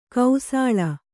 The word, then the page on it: ಕಾಸುಳಿ: kāsuḷi